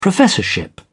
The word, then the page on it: professorship: British English